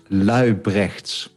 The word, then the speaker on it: Laubrechts: So it's Dutch, and it's Laubrechts.